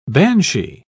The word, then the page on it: banshie: British English